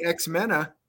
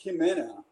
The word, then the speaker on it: Ximena: I think it's Ximena. It's like pronounced with a J. So Soshi is pronounced with an S, and Ximena is pronounced with a J sound.